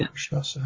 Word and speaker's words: Rakshasa: Rakshasa. I had to worry that funny. Yeah. We know the word, don't we? But Rakshasa. Yeah.